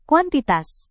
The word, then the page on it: kuantitas: indonesian